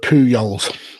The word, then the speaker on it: Pujols: It's Albert Pujols.